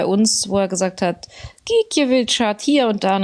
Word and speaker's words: Gikiewicz: Und da hat er dann irgendwie gesagt, Gikiewicz.